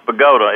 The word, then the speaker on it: Bogata: A little town spelled Bogata, but pronounced Bogota.